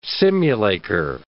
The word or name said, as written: simulacre